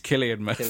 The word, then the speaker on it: Cillian Murphy: It's Cillian Murphy.